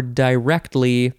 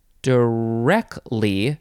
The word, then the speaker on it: directly: So directly or directly, this is the first form, and it does say that in sense two, it is often pronounced directly, no T sound, directly or directly. We're taking out a syllable there.